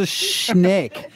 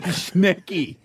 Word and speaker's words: schneck: It was a schneck. – The point of the game is he's not meant to pick the actual answer. – It's not a schnecky. There's no little accent on the end.